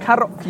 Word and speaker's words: Karoq: K-A-M-I-A. Kamiya. – Kamiya. – Karoq. Karoq. You got it wrong. Karoq. – Karoq.